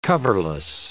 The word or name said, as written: coverless